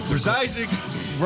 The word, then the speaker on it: Zizek: Oh, Zizek's rhymes with 66 somehow.